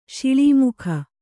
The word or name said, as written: ಶಿಳೀಮುಖ